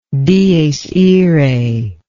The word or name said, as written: dies irae